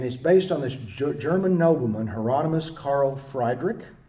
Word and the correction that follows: Friedrich: Friedrich, thank you. Put the long I on it. I'm from Oklahoma.